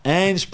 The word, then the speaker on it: Ange: Ange Postecoglou this week.